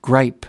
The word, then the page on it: gripe: American English